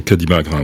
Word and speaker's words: Magra: Et puis, euh, l'été, avec euh, beaucoup d'amis, ils euh, sont à, alors là je prononce très mal, à Magra. di Magra, oui.